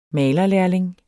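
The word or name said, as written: malerlærling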